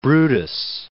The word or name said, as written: Brutus